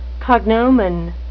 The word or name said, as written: cognomen